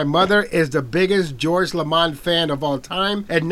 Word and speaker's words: LaMond: I love that pronunciation on the D. He said George LaMond and George Anthony.